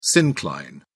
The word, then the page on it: syncline: British English